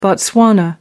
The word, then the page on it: botswana: American English